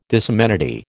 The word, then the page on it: disamenity: American English